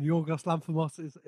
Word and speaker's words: Yorgos Lanthimos: As a Greek man, Yorgos Lanthimos is a perfect pronunciation.